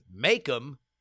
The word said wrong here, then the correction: Macomb: I guess it was like Macon, Georgia for me, is indeed pronounced Macomb. Said much like Macomb, thanks to the inundation of the French in the early days of colonization.